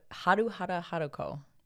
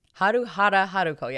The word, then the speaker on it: Haruhara Haruko: I want to make sure I'm saying this right. How do How do you? Haruhara Haruko. Yes, I was right.